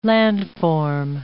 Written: landform

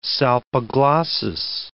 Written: salpiglossis

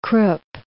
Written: Krupp

